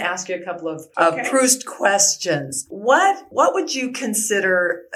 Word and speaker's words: Proust: I think it's pronounced Proust.